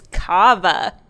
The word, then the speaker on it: cava: No, it's cava.